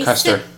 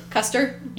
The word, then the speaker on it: Custer: Yeah, Jesse Custard is a Custer. – Custer. – You said Custard.